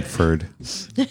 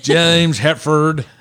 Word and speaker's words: Hetfield: You said it wrong. Did I say? So we're all laughing at you. – Okay. Hetfield. – James Hetfield. Written by Cliff Burton, James Hetfield. James Hetfield. There you go.